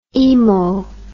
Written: imo

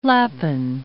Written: lapin

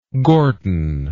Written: Gorton

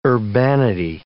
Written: urbanity

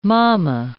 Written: momma

